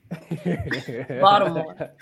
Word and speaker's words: Baltimore: Yeah, there's no T in Baltimore, okay? The T is silent. – Baltimore.